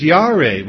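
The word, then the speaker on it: yare (ירא): The Hebrew word translated afraid in verse 10 of chapter 3 is yare. We've covered this word before. It's spelled Y-A-R-E, which means to fear or to be frightened or to be intimidated.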